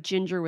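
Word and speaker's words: Jinger: It's like Ginger with a J. Come on. – Yeah. – Yes, which everyone thinks rhymes with finger because it looks like it should.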